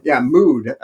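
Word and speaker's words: Müd: Mood. It's spelled MUD, M-U-D, with a little umlaut over the U.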